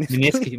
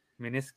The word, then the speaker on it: meniscus: Min- Min- Miniski. Miniski. I can't fucking say words. Fuck. I, I, I, I, think you said it right. Meniscus.